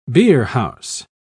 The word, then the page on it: beerhouse: British English